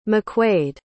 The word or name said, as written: MacQuaid